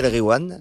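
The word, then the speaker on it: Nass El Ghiwane: Nas El Gwiam. – Nas El Gwiam, voilà. – Et quand il y a GH, tu dis re. Tu fais comme si c'était un R.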